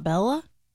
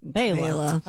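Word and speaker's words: Béla: Born in 1958, the great banjo player, Mr. Fleck, first name B-E-L-A. – B-E-L-A. It's not Bella? – It's Bela. – Bela.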